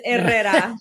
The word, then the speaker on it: Herrera: Yeah, but there's no H, there's Herrera.